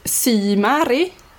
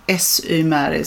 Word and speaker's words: SY Mary: Jag har alltid sagt Symäri. – Mm. – Och det stämmer inte alls, för seglarfolk tycker att jag är jättekonstig och uttalar det helt fel. – Ja, det, det är egentligen SY Mary som är Sailing Yacht Mary.